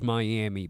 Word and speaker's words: Miami: Yes, Miami, spelled Miami, but pronounced Miami.